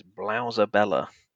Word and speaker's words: blowsabella: So, I would like you to have a guess at what this is, if you please, like a serious guess, because this word is Blouserbella. Blouserbella. I can spell that for you, which is BLOWSABELLA.